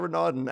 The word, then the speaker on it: Renaudin: Well, my name's Josiah Renaudin, as you'd imagine, it's been butchered by so many people, and like I'm usually okay with it until someone's like, right.